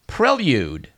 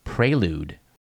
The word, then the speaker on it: prelude: and it is correctly prelude, not prelude.